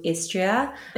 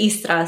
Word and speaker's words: Istra: So I have a 7-day itinerary through Istria. In Croatia, we pronounce it Istra, so you drop the second I.